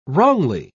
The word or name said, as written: wrongly